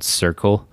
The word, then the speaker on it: Cercle: I think they're based in France, called Circle. – Yeah, and it's spelled C-E-R-C-L-E. And they just have like really epic house and techno DJs playing in really epic spots.